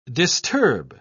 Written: disturb

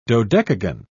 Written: dodecagon

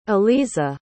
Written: aleezah

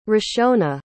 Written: rishona

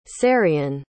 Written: cerian

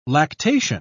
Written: lactation

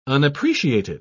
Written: unappreciated

